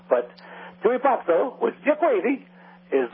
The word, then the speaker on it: pazzo: Means you're crazy, and "pazzo" is spelled P-A-Z-Z-O, like pizza, pazzo.